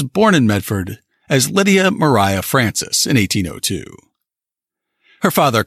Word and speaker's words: Maria: Lydia Mariah Francis always hated the name Lydia, so she went by her middle name, which was spelled Maria, but she pronounced it as Mariah.